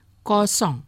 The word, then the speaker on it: kosong: Kosong, kosong.